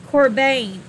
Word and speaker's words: qorban: In the Strong's Exhaustive Concordance, it is pronounced Corban, or spelt Q-O-R-B-A-N, meaning something brought near the altar, i.e.